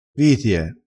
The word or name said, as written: vitie